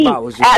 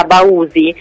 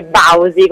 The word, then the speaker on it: Bausi: Allora, è giusta, però in realtà pensa che è un cognome fiorentino e lo cambiò mio nonno in accademia militare, perché in realtà prima l'accento era sulla U, era Bausi, che non si può sentire, e lui decise di farsi chiamare Bausi con questo accento molto forte sulla A, quindi in realtà cui, insomma, ci ho preso.